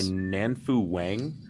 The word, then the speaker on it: Nanfu Wang: It is on HBO, and was it directed by Nanfu Wang? – Thank you.